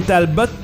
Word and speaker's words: Talbot: Et Caroline Talbot, il faut prononcer le T, nous a-t-on appris à la Réunion avant le match lundi. – Oui, comme le joueur de hockey Maxime Talbot.